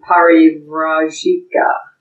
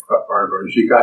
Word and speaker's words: Parivrajika: V-R-A in Parivrajika. – Okay, Parivrajika. I spelled it Parivrajika. – P-A-R-I-V-R-A-J-I-K-A.